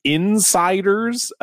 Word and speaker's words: Insyderz: Then there's the Insiders, but it's spelled Insyderz, and they were an American Christian ska band.